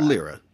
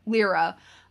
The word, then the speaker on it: Lyra: is it Lyra or Lyra? – Lyra. – Lyra.